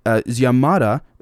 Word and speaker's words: Xiomara: With more than half the votes counted, Xiomara, X-I-O-M-A-R-A, hopefully I didn't butcher that, Xiomara Castro has led, of 20 percentage points over the ruling party candidate, Asfura.